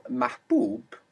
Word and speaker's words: mahbub: And you can look out for this ma with this long u to recognize this pattern. – So mahbub, hubbub, refers to liking or loving.